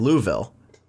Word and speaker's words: Louisville: And then it goes towards, like, Louisville. – Oh, shit. – So nice pronunciation of Louisville, by the way. – Yeah, that's how it's pronounced.